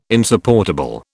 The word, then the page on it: insupportable: British English